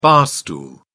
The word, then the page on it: bar stool: British English